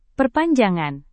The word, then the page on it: perpanjangan: Indonesian